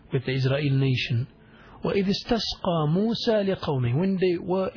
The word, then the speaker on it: استسقى: استسقى is alif seen, ta seen, qaf and ya maqsura, which is pronounced as an alif. استسقى, try to pronounce it as I'm saying it.